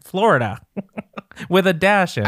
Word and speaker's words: Flo Rida: And let me tell you, it took me perhaps 5, perhaps 6 years to realize that his name is Flo Rida and it's spelled Florida.